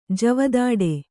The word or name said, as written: ಜವದಾಡೆ